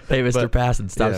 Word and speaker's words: Passan: And uh, Passon, is it Passan or Passon? I, I can't pronounce this. – It's Passon. – I hope if you ever have him on and you call him Passan, he'll immediately hang up and never do your show again.